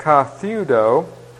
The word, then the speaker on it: katheudo: But katheudo, K-A-T-H-E-U-D-O, and the long O again.